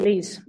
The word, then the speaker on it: Lee: Yes, it's Lee. It's like Lisa, okay, great. – The Estonian version.